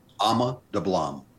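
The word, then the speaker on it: Ama Dablam: Ama Dublam.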